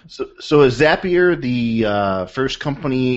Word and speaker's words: Zapier: Is how many times have you heard people call it Zapier, which I mistakenly called it last week, but the correct way to pronounce it is Zapier, right? – Correct. Zapier makes you happier is the trick.